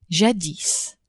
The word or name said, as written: jadis